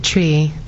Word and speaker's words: Twi: No, you pronounce it tree. So it's like a tree. – Kind of, but mm-hmm. It's like it's T W I. That's how you spell it. But it's like a mix of an R and a W.